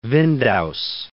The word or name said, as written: Windaus